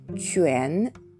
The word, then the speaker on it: 泉: そして 2 つ目の文字が、えー、日本語で読むと泉という文字になるんですが、チュエン、チュエンという文字になります。発音記号は QUAN で、えー、第 2 声、下から上にずり上がる音になります。